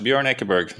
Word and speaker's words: Bjorn Ekeberg: Bjorn Ekeberg.